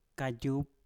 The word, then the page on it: kajup: Mpur